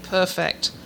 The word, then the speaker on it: perfect: And I read this and I thought, I'm trying to make it perfect. I am not trying to perfect it. Have I got that right? – That's exactly right. Right. It's a totally different pronunciation. It's perfect, not perfect.